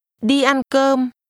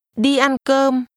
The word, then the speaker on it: Đi ăn cơm: Đi ăn cơm. Đi ăn cơm. – Make sure that you pronounce all the mid-level tones at the same pitch.